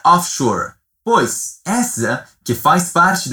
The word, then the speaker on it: offshore: A pronúncia dela é como em inglês, offshore.